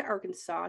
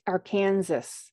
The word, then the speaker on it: Arkansas: So he just basically went on with life and married his college sweetheart, Betty Hester, and moved to Arkansas to become a teacher until the school. Yep. – You said Arkansas? It says Arkansas. – Yes. Here in America, we pronounce that Arkansas.